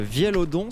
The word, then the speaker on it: Vielodon: Le Vielodon, ça se prononce bien comme ça, le Vielodon.